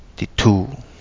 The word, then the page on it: Di Tuu: Kapingamarangi